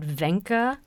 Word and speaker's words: Wenche: Is this Norwegian architect named Wenche Selmer. Now, that's how you pronounce it, Wenche. – So I'm told. Yes, it's spelled W-E-N-C-H-E. – Right. You know, you have to be told it's pronounced Wenche.